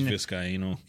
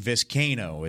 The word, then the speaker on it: Vizcaino: I believe there's like a weird back and forth on like how to say his name, but I have seen Vizcaino. – Yeah, see, I've seen Vizcaino is how you say it, with Kane, C-A-I-N, capital.